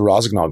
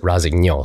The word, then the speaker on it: Rossignol: Uh, kind of overseeing all the hard goods, skis, boot bindings uh, for the Rossignol Group so for Rossignol for that side of it.